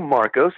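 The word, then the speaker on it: Markos: Well, my name is Lou Marcos, and it's spelled K-O-S, which will let you know that I'm Greek.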